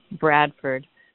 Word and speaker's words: Bradford: I see a native Bradford person saying the pronunciation is Bradford, which is a wonderful Vermont dialect, but most of us, I think, say Bradford with the emphasis on Brad and Ferd, like FERD.